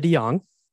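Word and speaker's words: DeJonge: Olivia De Jong.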